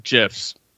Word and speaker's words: GIF: I've only seen GIFs. – Mm-hmm. – That dude's dead, so I can pronounce it correctly now. – What? That guy's dead, so what? – I can pronounce it correctly now. GIF.